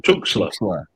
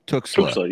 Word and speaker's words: Tuxtla: Talk to us a little bit about the, there's also the, and I'm I always butcher the pronunciation, Tuxtla. – Tuxtla. – You got it right.